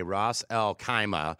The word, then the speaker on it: Ras Al Khaimah: It's the sixth largest city, abbreviated RAK, Ras Al Khaimah.